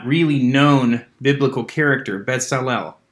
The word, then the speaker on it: Bezalel: Yeah, Bezalel.